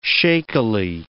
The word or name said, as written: shakily